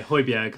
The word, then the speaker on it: Højbjerg: And then we signed Højbjerg. I can never pronounce his name. – Yeah, Højbjerg.